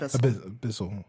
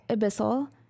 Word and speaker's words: Abyssal: So the book that you're holding, it says it's in Abyssal. Do I say that right? – Abyssal. – Abyssal.